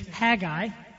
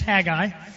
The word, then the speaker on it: Haggai: His name is Haggai if you've been saying it wrong all this time, you can pretend like you knew. So his name's Haggai.